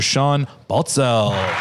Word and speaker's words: Sean Baltzell: Our guest today, Mr. Sean Baltzell.